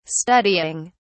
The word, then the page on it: studying: British English